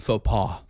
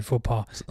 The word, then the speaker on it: faux pas: Faux pas. – Thank you, faux pas.